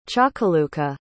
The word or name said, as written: chakaluka